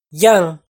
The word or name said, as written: Yaaj